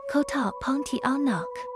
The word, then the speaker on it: Pontianak: 印尼语发音 Kota Pontianak，因为当时的第一任苏丹刚到那个地方，就被一只庞蒂亚纳给吓到了。